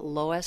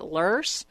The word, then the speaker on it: loess: And several authors, Ed Cans and several other authors as well as Erica, talk about loess soils. Now, you can pronounce it lurse or loess or loess.